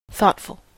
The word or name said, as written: thoughtful